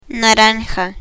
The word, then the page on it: naranja: Spanish